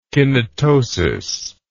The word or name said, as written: kinetosis